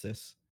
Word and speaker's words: cis: I hate that it's pronounced cis. It looks like it should be psi.